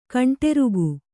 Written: ಕಂಡ್ರಿಕೆ

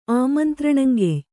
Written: ಆಮಂತ್ರಣಂಗೆಯ್

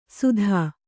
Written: ಸುಧ

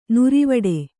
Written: ನಿರಿವಿಡಿ

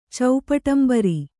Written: ಚೌಪಟಂಬರಿ

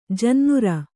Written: ಜನ್ನುರ

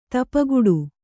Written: ಧೂಪಘಟ

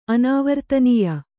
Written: ಅನಾವರ್ತನೀಯ

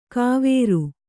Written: ಕಾವೇರು

